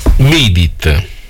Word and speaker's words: Beat It: Beat It. L'ho detto bene? – O penso di sì.